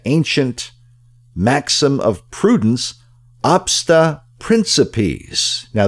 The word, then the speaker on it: principiis: I had never heard of it before, but obsta principis, that's O-B-S-T-A, first word, second word, principis, P-R-I-N-C-I-P-I-I-S, and it's pronounced principis.